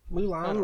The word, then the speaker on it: Moulin: I think it's Moulin. Moulin Rouge? – Moulin. Yeah. Moulin, like the Warrior of China? – Yep.